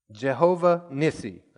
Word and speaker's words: Jehovah Nissi: Jehovah Nissi. That's how we pronounce it.